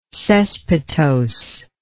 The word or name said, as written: cespitosely